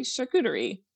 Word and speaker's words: charcuterie: A good way to remember how to pronounce it, because the emphasis is on the right, you know, syllables, and you can kind of go Sean Connery charcuterie.